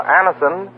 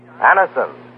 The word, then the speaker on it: Anacin: Just ask for Anison at any drug counter. It's spelled A-N-A-C-I-N. Anison.